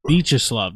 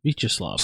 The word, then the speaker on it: Viacheslav: Vyacheslav, that's how you say it. All right. Vyacheslav.